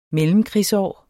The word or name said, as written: mellemkrigsår